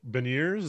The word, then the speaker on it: Beniers: It's Beniers. Is that how you pronounce it? – Yes.